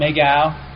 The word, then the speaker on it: McGow: It's actually, my dad was Portuguese, so it's McGow is how they pronounce it over there, but it became Mago over here years and years and years ago, so that's how it's pronounced.